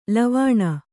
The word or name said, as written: ಲವಣಿ